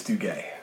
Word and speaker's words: Duguay: I'm Keith, and tonight I'm joined by Rob, am I say it right, Duguay? – It's Duguay. – Duguay, yeah. – All right. – Yeah. French-Canadian.